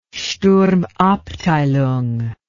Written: sturmabteilung

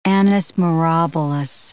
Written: annus mirabilis

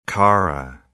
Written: Kara Sea

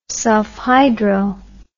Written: sulphydryl

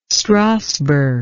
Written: strasberg

